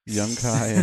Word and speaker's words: Yunkai: Yeah, I think Yunkai? – So. I think that's how you pronounce it. That sounds good. – Kind of sounds like ass high. – Yunkai and ass high.